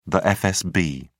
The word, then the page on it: fsb: British English